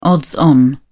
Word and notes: odds on: British English

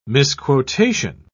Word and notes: misquotation: British English

